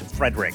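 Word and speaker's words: Friedrich: Spelled like fried rich, but it's Frederick.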